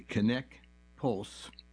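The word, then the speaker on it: Conneqt Pulse: Finally, the best blood pressure monitor for the most comprehensive data is the Kinect Pulse, that's spelled C-O-N-N-E-Q-T, Kinect Pulse, retails for $289. Additional monitoring. The Kinect Pulse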